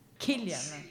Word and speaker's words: Cillian: How to pronounce Cillian Murphy correctly? Killian. – Killian. Killian Murphy, non Cillian.